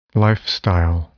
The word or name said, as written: lifestyle